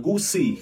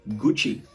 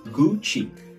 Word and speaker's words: Gucci: đừng đọc là gucci khi có hai chữ c bên cạnh nhau nó thường đọc là chờ gucci gucci